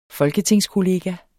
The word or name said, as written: folketingskollega